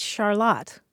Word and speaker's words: Charlotte: But the people who pronounce it Charlotte are in Vermont.